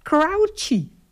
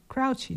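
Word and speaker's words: Croce: Er stond dus gewoon bij de Wikipedia, stond dus, je spreek je uit als, ik denk, oh, maar dat is mooi. Crouchy. – Crouchy, nou ja, dus zat ik toch in de buurt.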